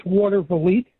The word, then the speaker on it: Watervliet: Let's go to Watervliet. I think that's how you pronounce it. New York, the great WGD. How do you pronounce that? – It's Watervliet. – I was close.